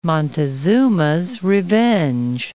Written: Montezuma's revenge

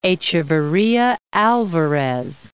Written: Echeverria alvarez

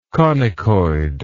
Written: conicoid

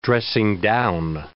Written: dressing-down

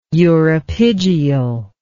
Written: uropygial

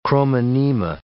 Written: chromonema

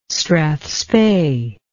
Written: strathspey

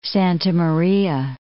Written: Santa Maria

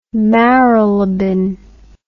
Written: Marylebone

